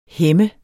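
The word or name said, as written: hæmme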